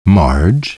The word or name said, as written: Marge